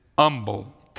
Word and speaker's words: Humble: Now that's how I would say it, Humble, Texas. They pronounced it Humble, Texas. And they would argue over that the H is silent.